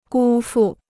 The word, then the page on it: 辜负: gū fù